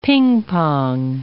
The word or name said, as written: ping-pong